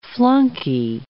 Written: flunky

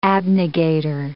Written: abnegator

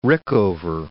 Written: Rickover

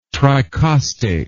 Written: tricostate

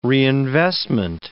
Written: reinvestment

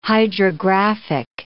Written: hydrographic

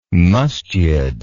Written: musjid

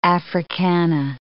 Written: Africana

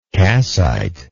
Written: cassite

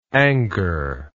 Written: angor

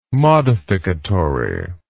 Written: modificatory

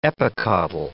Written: epicotyl

